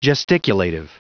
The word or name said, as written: gesticulative